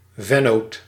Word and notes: vennoot: /vɛn.no:t/

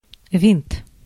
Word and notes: propeller: /pɹəˈpɛl.ə(ɹ)/

